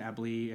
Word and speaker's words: Eble: So you can find me on Instagram at Christian Eble.